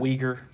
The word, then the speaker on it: Uyghur: Is that how you pronounce it? – Uyghur. – The Uyghur. – Uyghur. – Uyghur. – I like your pronunciation.